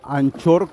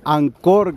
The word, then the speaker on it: Anchor: Hay herramientas como anchor, como ancla, que se llama en, español, Ancor, que es realmente la pronunciación, nosotros decimos anchor en varias, en varias, en en varios lugares por según el acento, pero el nombre es Ancor, en español es Ancla,